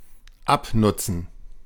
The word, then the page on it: wear: American English; [wɛr]; British English; /wɛə/; /wɛɚ/